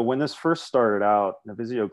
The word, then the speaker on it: Navisio: Navisio Global.